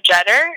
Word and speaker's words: Jetter: So Facebook, you just look at my name, Tina Jeter, and it's spelled J-E-T-T-E-R.